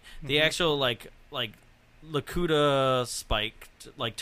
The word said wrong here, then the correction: Lakitu: And Lakitu.